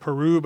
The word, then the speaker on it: Piru: I'm calling it Peru, but it's spelled P-I-R-U.